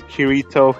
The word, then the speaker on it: Kirito: So, my name is Kirito Himura. – Or how the hell do you spell that? – K-I-R-I-T-O H-I-M U-A-R-A.